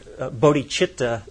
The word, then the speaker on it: bodhicitta: Bodhicitta, or we pronounce it bodhicitta here,